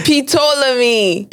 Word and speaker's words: Ptolemy: No, because Ptolemy is spelled PT. – Whoa. Wait. Ptolemy.